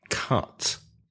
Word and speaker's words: cut: In words like cut or run, it's not u, it's a, like up. So listen, cut, cut, cut.